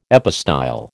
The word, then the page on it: epistyle: American English